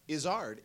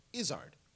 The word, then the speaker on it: Izzard: And it's actually not Izzard, it's Izzard. – He, like me, is constantly explaining the subtle mispronunciation. – It is very subtle. It's the emphasis on the first half, the first syllable, but it's the second syllable is pronounced ard, not erd.